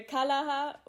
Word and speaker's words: Kalaha: das basiert auf einem Spiel, so steht es zumindest auf eurer Homepage, das ich vorher nicht kannte, Kalaha. Wird das so ausgesprochen? – Ja, also ich sage auch äh, Kalaha und ähm, genau, kommt so ein bisschen aus der Spielfamilie auch Mankala.